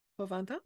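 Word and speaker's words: pour vingt ans: Florent, merci beaucoup pour 20 ans. Did I say it right? Pour 20 ans? – Yeah, it's correct.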